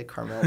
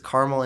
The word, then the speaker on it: Carmel: I always say Carmel like the car company. – Right. – It's Carmel, Indiana. He went to Purdue University, and he recently left Purdue and is now a professional diver.